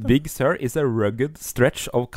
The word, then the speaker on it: Big Sur: Watch OS 7 og Mac OS Big Sur, eller hvordan uttaler jeg det? – Big Sur, ja. – Big Sur? – Ja.